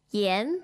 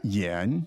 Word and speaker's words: 盐: How do you say salt in Chinese? – 盐. Yan. – Yan. – Yes. – Second tone.